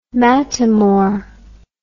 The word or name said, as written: mattamore